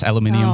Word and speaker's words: aluminium: And, is it in, don't the Brits say aluminium? – Yes, aluminium. – Oh, I like aluminium. – Dave, can you, producer, can you confirm that? Aluminium? – Yeah, that is true.